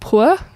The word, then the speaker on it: Puer: This was the juvenile prison called Point Puer. That's how I pronounce it. P-U-E-R.